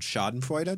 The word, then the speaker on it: Schadenfreude: what I was wondering because that I think, as Eric often says, it's about his favorite word, Schadenfreude. Did I pronounce it correctly? Right. Yes.